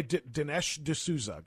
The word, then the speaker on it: Dinesh D'Souza: You know, when you interviewed Dinesh D'Souza, I hope I pronounced that right. – No, it's okay. Dinesh D'Souza, got it.